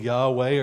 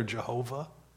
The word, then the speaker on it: Yahweh: And the correct pronunciation, we go with Yahweh or Jehovah.